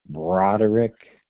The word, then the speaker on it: Broderick: John Broderick, and it's spelled B as in boy, R-O-D, as in dog, E-R-I-C-K.